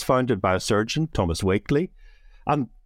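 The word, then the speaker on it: Wakley: Well, the first thing to say, Martin, is there's a lot of controversy about how to say his name. So we have been brought up at The Lancet to call him Thomas Wakeley.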